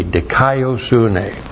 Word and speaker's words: dikaiosune: D-I-K-A-I-O-S-U-N, long E, dikaiosune.